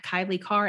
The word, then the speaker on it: Kailei Carr: I'm at Kailei Carr everywhere.